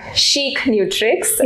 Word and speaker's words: ChicNutrix: Coming to the name, Chic Nutrix, yeah, as we pronounce it. And I know there are different pronunciations that we hear every time, but it was the word chic, if you know, it means stylish. It means something we say, it's chic.